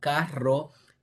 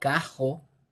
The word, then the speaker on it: carro: So instead of carro, it would be something like cajo or cago.